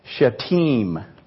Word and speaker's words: Shittim: So this place that they left from, Shittim, is actually pronounced Shittim.